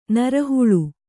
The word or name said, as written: ನರಹುಳು